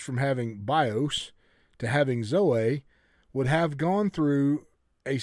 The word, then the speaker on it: zoe: The word is Z-O-E, but it is actually pronounced Zoe.